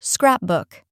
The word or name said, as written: scrapbook